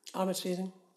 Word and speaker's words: Armatrading: Joan Armatang or Tag? – Armatrading. – Armatrading, that's it.